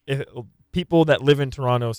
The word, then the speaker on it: Toronto: Note to our American broadcast friends, Toronto is pronounced Toronto. The second T is silent.